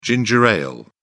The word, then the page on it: ginger ale: British English